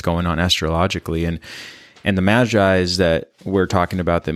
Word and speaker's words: Magi: I know that, but if you don't know what the Magi's are, and I'm not pronouncing that correctly. – Yeah, so, Magi, you got it.